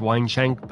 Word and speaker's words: Weinschenk: Her name's pronounced Weinschenk, but it's spelled W-E-I-N-S-C-H-E-N-K.